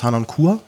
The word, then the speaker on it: Harnoncourt: Wie gesagt, die Aufnahme, die wir haben, ist von Nicolas Harnoncourt.